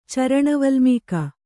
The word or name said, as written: ಚರಣವಲ್ಮೀಕ